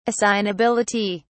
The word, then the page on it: assignability: British English